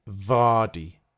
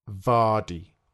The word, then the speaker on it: Vardy: When you make the V sound, you put your teeth over your bottom lip. So you say Jamie Vardy. Vardy. – Let's try saying this. Repeat after me. Vardy.